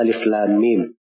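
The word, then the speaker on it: Alif lam mim (الم): Alif Lam Mim kan begitu yang disebut huruf muqatta'ah Makanya cara membacanya itu terpisah. Alif lam mim. – Jadi dibaca terpisah.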